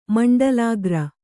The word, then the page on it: ಮಂಡಲಾಗ್ರ: Kannada